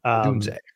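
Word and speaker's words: Odunze: Adusie. Is that how you say it? – Adunze.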